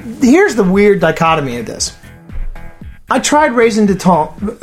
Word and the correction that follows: raison d'être: How did you pronounce it again, Martha? – Raison d'être. – Raison d'être. – Let me pronounce that clearly. Yes. – R on the end of it.